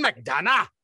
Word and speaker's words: McDonagh: But you got to say it like this. Martin McDonagh.